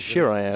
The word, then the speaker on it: Shiryaev: Dennis is spelled D-E-N-I-S, and then I'm not even... – Shirayev. – Shirayev, spelled S-H-I-R-Y-A-E-V.